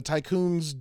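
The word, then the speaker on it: Tiqqun: But you're this big colony ship, essentially, called the Tycoon, which is spelled weird. It's T-I-Q-Q-U-N. And the Tycoon's job is to test out this, like, new Vol engine.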